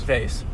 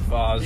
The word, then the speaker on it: vase: Is it pronounced Voss or Vase? – Vase. – Vase? You're a Vase guy? – Yeah, I don't fuck with Voss.